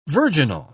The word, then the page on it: virginal: British English